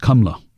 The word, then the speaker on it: Kamala: It's actually pronounced Kamla, like K U M L A in India.